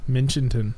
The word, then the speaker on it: Minchinton: Minchinton.